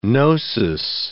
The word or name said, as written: gnosis